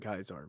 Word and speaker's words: Caesar: If you'll, you technically, they would be saying Kaiser, right? – Yeah, well, that is in Latin. – In Latin. – Caesar, a hard C.